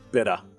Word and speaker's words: beta: Pronounce B-E-T-A. How do you say it in Australia? – Better. – You say beta, okay. – Like a beta fish. – A lot of people in Europe will say beta.